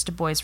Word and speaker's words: Du Bois: In his essay, Black Reconstruction in America, W.E.B. Du Bois is how he pronounced his name.